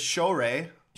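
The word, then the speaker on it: Shohreh: It's Shorey. – Shorey. – No N.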